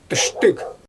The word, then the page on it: tɯʃtɯɣ: Xyzyl